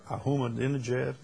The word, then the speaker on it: Ahmadinejad: Ahmadinejad, yeah.